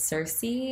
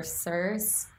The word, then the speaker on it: Circe: And then another book by that same author, which is still Greek mythology, is Circe or Circe. People say it different ways. I listen to it, like how to pronounce it on Google. There's so many different ways. Obviously, like the Greeks say it differently, but it's C-I-R-C-E.